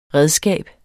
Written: redskab

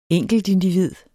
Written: enkeltindivid

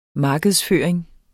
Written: markedsføring

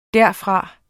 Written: derfra

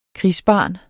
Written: krigsbarn

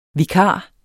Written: vikar

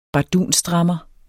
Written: bardunstrammer

